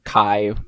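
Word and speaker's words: Kei: It's Kai? – I've been pronouncing it Kai because it's K-E-I in most translations I can find.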